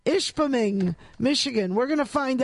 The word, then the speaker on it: Ishpeming: It's located in Michigan in a place called Ishpeming, which is spelled exactly the way it sounds.